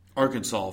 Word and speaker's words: Arkansas: Akron-SAS is ranked 16th. – Arkansas for the rest of the people.